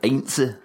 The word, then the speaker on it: Heinze: I'm glad you took care of that lineup there, because I'm still to this day not really sure how to pronounce Heinze. – Gabriel Heinze. – I just know it's not Heinz, as most people would have said it.